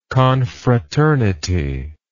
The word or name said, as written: confraternal